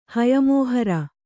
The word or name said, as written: ಹಯಮೋಹರ